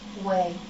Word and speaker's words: whey: Only Madhu Mangal, the Brahmin, had only a little vay. Now, I might not say it properly. Is it the right pronunciation? Vay? When you churn butter from milk, you get the butter and... – Way.